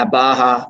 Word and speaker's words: Baja: I don't think I said that right, but is it Budger? – Nah, Baja 1000.